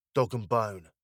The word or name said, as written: dog and bone